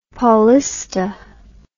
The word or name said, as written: Paulista